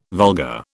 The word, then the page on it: vulgar: British English